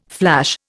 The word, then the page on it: flash: Indian